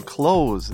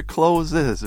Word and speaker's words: clothes: Everyone notice the pronunciation close. It's always close. Never, never closes, right? Uh, my yes. students sometimes make that mistake.